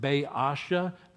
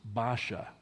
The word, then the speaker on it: Baasha: Some pastors pronounce it Baasha. That's not faithful to the Hebrew. He is pronounced Baasha.